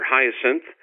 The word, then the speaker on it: Hyacinthe: The other very important speaker we had was a Father Hyacinthe, or Hyacinthe, as he pronounces it in French, Hyacinthe d'Estevelle, from the office in the Vatican of the Pontifical Council for Christian Unity.